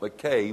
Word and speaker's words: Mackay: So in Scotland, as I pointed out, Mackay would be pronounced as the word I.